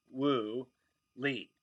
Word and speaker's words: Wu Li: with the same exact English spelling and each pronounced in English, Wu Li.